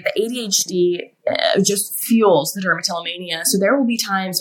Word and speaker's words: dermatillomania: You also have a form of OCD called dermatillomania. Did I pronounce it right? – Yes.